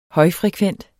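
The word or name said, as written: HØJFREKVENT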